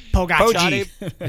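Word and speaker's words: Pogačar: It all ended up coming down to the time trial on day 20 of 21, where Pogacar, and I think I'm finally saying it right. – Thank you. – Pogacar. – His friends call him Pogacar.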